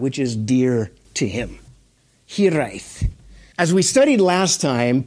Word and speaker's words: hiraeth: You can say that with a Welsh, to say it properly, hiraeth. You have to kind of roll the R.